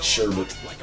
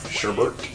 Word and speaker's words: sherbet: There's no other R in it. There's only one R. It's sherbet.